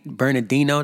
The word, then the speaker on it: Bernardino: Bernardino that's how you say it, San Bernardino, California. I, I believe so he's about to kill that.